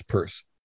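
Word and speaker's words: Peirce: And I guess I should, just for the record, correct you on the pronunciation of C.S. Pierce's name, too. It's Pierce.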